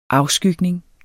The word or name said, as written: afskygning